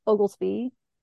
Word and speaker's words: Oglesby: Okay, so my name is Lisa Ogelsby. It's a weird last name. It's spelled O-G-L-E-S-B-Y.